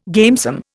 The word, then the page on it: gamesome: Indian